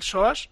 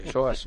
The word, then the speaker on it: psoas: sin molestias en el psoas. Hoy lo he dicho bien, ¿verdad? – Muy bien. – Sí, psoas, normal.